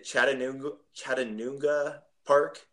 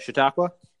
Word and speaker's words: Chautauqua: Is it Chattanooga Park? Is that how you say it? – Chautauqua.